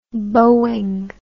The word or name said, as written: Boeing